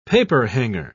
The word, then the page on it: paperhanger: British English